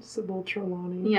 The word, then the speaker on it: Trelawney: Why would Marge be able to play Snape in a remake of Harry Potter, and why would my mother perfectly play Sybil Treat? How do you say her name? – Sybil Trelawney.